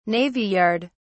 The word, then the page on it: navy yard: British English